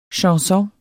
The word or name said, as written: chanson